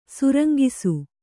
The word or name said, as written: ಸುರಂಗಿಸು